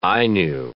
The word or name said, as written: Ainu